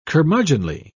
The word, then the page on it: curmudgeonly: British English